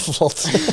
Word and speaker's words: Vlad: Oh, de Vlad zal wel uitgesproken worden, zeker? – Vlad. – Ja.